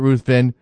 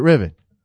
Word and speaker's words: Ruthven: who doesn't pronounce it Ruthven. I don't think he does. I think he pronounces it Riven.